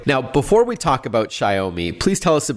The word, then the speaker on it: Xiaomi: And they're called Xiaomi. It's spelled X-I-A-O-M-I.